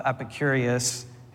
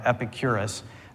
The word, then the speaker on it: Epicurus: But then there's the Garden of Epicurus, who you might not, I didn't even say that right, Epicurus, and the Painted Porch of Zeno.